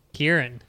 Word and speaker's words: Ciaran: Claren Campbell asks. Oh no, how do you pronounce this? C I A R A N Campbell. – Kieran.